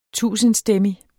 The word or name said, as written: tusindstemmig